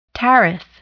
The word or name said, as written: tariff